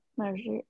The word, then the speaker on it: Mazariego: Andreas Mazariego. Mazariego. – That Okay. – sounds right to me. – Mazariego?